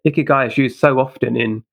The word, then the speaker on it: Ikigai: Oh, I'm sorry, most people mispronouncing it. – Yeah, so it's Ikigai.